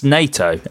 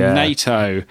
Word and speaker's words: Nato: Pronounced NATO, and that's the NATO.